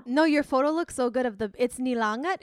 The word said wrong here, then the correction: Nilaga: is that what it's called? I forget. – Nilaga, I butchered it.